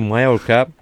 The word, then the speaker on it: Mallorca: Bugün Mallorca. Orada 2 L olunca Y okunuyor. Mallorca mesela Mallorca değil. Değil mi? Hani Mallorca.